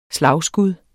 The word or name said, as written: SLAGSKUD